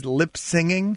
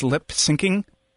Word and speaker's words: syncing: Did you not know that it's lip syncing? – Whatever. Yeah, me. I knew. If I knew it, I would have said it correctly. – Yeah, it's syncing up the lips with the music.